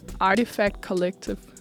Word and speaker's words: Artifact Collective: at de hedder Artifact Collective. Er det også sådan, I vil udtale det?